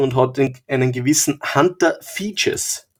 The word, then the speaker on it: Fejes: Hunter Features. F-E-J-E-S.